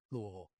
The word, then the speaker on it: Louisville: I love how you pronounced it correctly. Louisville.